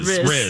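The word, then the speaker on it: rizz: She wasn't Rez. Her Halloween activities were not Rez, you know? Or Riz. – Riz. Riz. Say it right.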